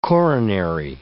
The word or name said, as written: coronary